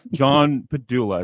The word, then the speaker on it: Padula: John Padula. Is that how you say your last name? – Yeah, John Padula. – Padula? – Padula. – Padula. – Yeah. – Like the brain thing? Padula Oblongata? – Padula Oblongata.